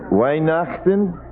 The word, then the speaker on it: Weihnachten: How do you say it in German? Weihnachten? Weihnachten.